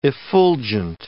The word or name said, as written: effulgent